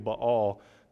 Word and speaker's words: Baal: The Hebrew would have been Baal. There's not really a long A sound in the Hebrew most of the time, so Baal.